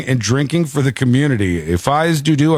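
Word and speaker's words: fais do-do: I'm shocked you aren't familiar with the term Fies Du Du. – Or Fie Do Do. – Yeah. – From the Fies Du Du. – That's how they say it. The locals say Fies Du Du.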